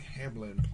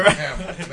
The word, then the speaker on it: Hamill: Hamill.